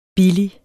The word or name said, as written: BILLIGSTE